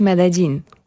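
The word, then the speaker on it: Medellín: Jak w ogóle mieszkańcy tego miasta wymawiają nazwę Medellin? – W kontekście języka kolumbijskiego, znaczy tak. Hiszpańskiego kolumbijskiego, to jest Medellin.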